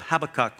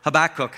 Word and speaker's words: Habakkuk: Well, last Sunday we began a brief two week series out of the Old Testament book of Habakkuk, or Habakkuk, however you say it, and we discovered last week, according to Google, that both pronunciations are okay.